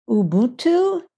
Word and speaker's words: Ubuntu: And he follows the theory called, I don't know if I'll say it right, everybody's Ubuntu, U-B-U-N-T-U.